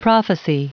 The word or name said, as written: prophecy